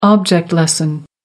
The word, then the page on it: object-lesson: American English